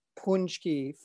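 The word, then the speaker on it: Pączki: And he wrote back Punchki phonetically.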